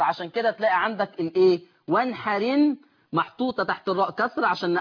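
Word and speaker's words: وانحر إن: وانحرن، مش بقى وانحر ان، لا، شيل الهمزه، انقل الحركه بتاعتها للحرف الساكن اللي قبل منها وانحرن شانئك.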